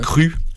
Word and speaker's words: Cru: Et donc c'est pour ça, donc on pourrait prononcer Jean Norton, mais ouais. Cru, par contre, à la française.